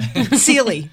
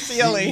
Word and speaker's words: ceili: Sealy. – Everybody says Sealy. – C-E-I-L-I. It's an Irish word that basically means hootenanny.